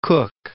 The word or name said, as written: Cooke